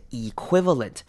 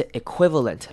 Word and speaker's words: equivalent: 好，接下来 is the equivalent，这边也是一样 the 念成 the，因为后面是 equivalent 这个字。 equivalent 呢有两种念法，可以念成 equivalent 或者是 equivalent，两个都可以，可以把 e 念长母音 e，或是把它做稍微省略 equivalent，这样子念也可以 equivalent。